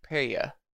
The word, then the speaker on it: peyot: heyas. – Yeah. – Did I say it wrong? – Hey. – Like heya. – Heya. – Heya. – I put it in the chat. P-E-Y-O-T. Peya.